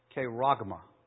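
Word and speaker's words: charagma: They're word pictures, and that's almost in the word used, because the word for mark in Greek is pronounced kairagma.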